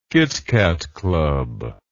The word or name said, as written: kit kat club